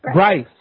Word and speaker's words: Bryce: Folks, you guys have been listening to Bruce Herless. – Bryce. – Bryce. Say it right. Bryce. B-R-Y-C-E.